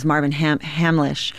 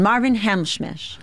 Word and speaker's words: Marvin Hamlisch: Marvin Hamlisch. And she said, so composer. – Yes. And she said, and Marvin Hamschmisch.